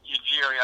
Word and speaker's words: Ujiri: Masai Ujiri, I don't mean, I probably butchered his last name. – No, you got it right.